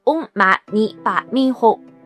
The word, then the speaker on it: Om Mani Padme Hum: 比如说我们之前提到的六字大明咒，不管传到哪个文化中，都是用梵文发音 Om Mani Padme Hum，而不会念它字面直译的意思，就是祈愿珍宝就在莲花中。